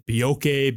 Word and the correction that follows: Boakye: Bioki, whatever. – Boakye, yeah.